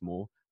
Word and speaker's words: Maw: Uh, his real name is Thomas Maw. I presume that's how you pronounce it. I haven't just made a hash that, have I? – T- yeah, Thomas Maw, yeah, M A W.